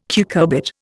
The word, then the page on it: cucurbit: Indian